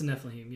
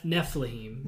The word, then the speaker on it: Nephilim: Is that how you say it? – It's a Nephilim, yeah. – Nephilim.